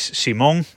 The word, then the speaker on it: Simón: una de las cosas es que la marca que fabrica interruptores y otros elementos eléctricos para, para casa eh, es Simón, no otras formas en las que dije el nombre, sino Simón, es una marca española y se pronuncia así.